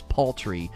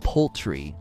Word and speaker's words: paltry: Yeah, the word is paltry, not poultry. I might have missaid it.